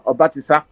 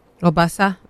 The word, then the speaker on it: Obasa: Obasa? Oh, sorry, is that not the pronunciation? Obatisa, Abi? Sorry, you know I'm a kubukubu. – Obasa, yeah, Obasa. I mean, Obasa, Obasa, Osakuru, Abi.